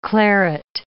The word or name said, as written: claret